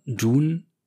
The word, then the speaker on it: Dune: Also Dune ist tatsächlich die richtige Aussprache.